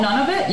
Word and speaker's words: Nunavut: Oh, Nunavut, yeah.